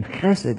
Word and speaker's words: chesed: Sometimes you'll see this if you have a transliterated like Bible software, and you see it's spelled H-E-S-E-D or C-H-E-S-E-D, because the H in Hebrew in this word is guttural, so it's like chesed.